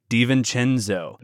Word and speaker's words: DiVincenzo: One other thing to watch for with the Milwaukee Bucks, we have rookie, I'm gonna butcher this, Dante DiVincenzo. – You got it. – Did I get that right? – You got it. – DiVincenzo.